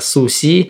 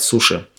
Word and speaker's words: суши: Суси угу. или сосими, поэтому в русском языке говорят суши. Так же, как, например, э, вагю или вагю.